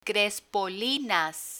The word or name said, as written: crespolinas